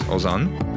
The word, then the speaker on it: Ozan: which is Ozan, that's spelled O-Z-A-N, to 345-345. So once again, that's my first name, Ozan, O-Z-A-N, to 345-345.